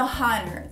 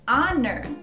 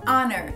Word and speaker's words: honor: Porém, em algumas palavras o H é mudo, como por exemplo honor. Eu não tô falando honor, tô falando honor. Tô começando com um som do O, honor.